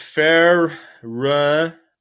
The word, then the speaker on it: Ferencváros: Next up, we had Bayer Leverkusen against Ferencváros.